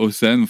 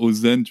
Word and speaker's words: Hosen: Ouais, je sais pas, tu vois, Osen, enfin voilà, je, je, tu vois, je, je pensais à Ozen, tu vois, déjà dans la prononciation.